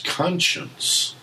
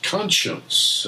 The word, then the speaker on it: conscience: it's conscience which is spelled like the word science with C O N in front of it and you wouldn't think it'd be pronounced this way, but that's conscience.